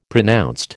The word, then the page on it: pronounced: American English